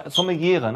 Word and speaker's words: Sommelier: Also es wird auch super, super spannend, weil Mona ist Sommeliere. Sommeliere, ne? Heißt das? – Sommelier. – Sommelier. Sommelier. Ja, das gendert man noch nicht.